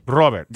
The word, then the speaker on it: Robert: Robert. – Got to roll the R a little bit.